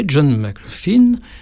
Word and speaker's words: John McLaughlin: Un jour, j'ai rencontré John McLaughlin à Radio Nova.